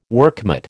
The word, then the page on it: workmate: American English